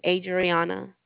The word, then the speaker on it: Adriana: Most likely you'll probably receive me on the next time that you call back, but just in case, my name is Adriana. – Spell that, please. – Yes, ma'am. A-D-R-I-A-N-A.